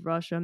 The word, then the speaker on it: rasa: Rasa means juice, essence, flavor. I'm pronouncing it with an SH because Bengali, but it's spelled RASA, Rasa culture.